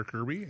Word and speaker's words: Curby: If you do go to AMEC Home Loans and put in either Darin, which is my legal name, D-A-R-I-N. – That's your name. – It is. Okay. It is. But I've gone by Kirby for many, many years. I spell it C-U-R-B-Y. So if you put that in, I am the only one in there under Kirby, and you can find me that way.